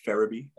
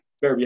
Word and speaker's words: Ferebee: My Instagram handle is only one Lige, and Lige is spelled L-I-J. So Instagram handle, only one Lige. On Facebook, Elijah Ferebee. My name will be posted, so yeah. I probably don't need to spell it. So Ferebee has a lot of E's. So Elijah Ferebee on Facebook.